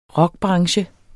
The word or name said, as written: rockbranche